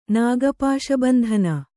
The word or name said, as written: ನಾಗಪಾಶಬಂಧನ